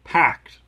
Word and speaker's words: Packt: So, Humble Bundle has this Java by Pact. That's spelled PACKT.